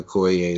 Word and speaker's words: Okoye: You might get a Okoye.